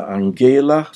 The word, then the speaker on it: Angela: De flyttade allihop några veckor efter Angela,